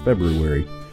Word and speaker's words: February: You notice I said that correctly. – You did have the extra R in there. – Yeah, there's a lot of people that say February. – Yeah. – Which is, I think, really the right way to say it, but I'm trying to be proper on my program here.